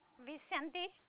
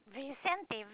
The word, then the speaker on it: Vicente: Florida Vicente. – And your last name? – Vicente, Vincent Victory. – Could you spell that for me? I apologize. – V-I-C-E-N-T-E.